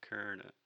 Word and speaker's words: Colonel: So Colonel is technically spelled C-O-N-O-N-E-L, I believe. Or Colonel. Yeah, but the way we pronounce it is Colonel.